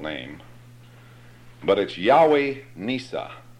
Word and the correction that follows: Nissi: or Nissi.